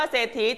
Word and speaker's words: เศรษฐี: เศรษฐีตัวนี้มีพยัญชนะต้น 2 ตัวแน่นอนแต่ออกเสียงเฉพาะตัวอะไรลูกตัวสอสาราใช่ไหม